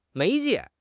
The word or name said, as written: 媒介